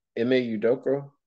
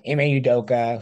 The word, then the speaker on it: Ime Udoka: And then over in the sports world, the Celtics coach, how do you say his name? – I think it's Ime Udoka. – Okay. – Udoka. – Yeah, okay. That's what I thought. Ime Udoka, who took the Boston Celtics to the Eastern Finals last year, Yeah.